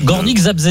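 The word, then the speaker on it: Górnik Zabrze: Gornik Zabrze.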